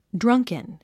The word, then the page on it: drunken: drʌ́ŋkən; ドランケン